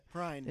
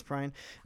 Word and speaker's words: Prine: And, you know, as of this recording, John Prine, or Prine, I'm honestly not quite sure how to pronounce it. – Prine. – It is Prine. Prine.